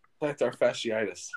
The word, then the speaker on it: plantar fasciitis: Plantar fasciitis.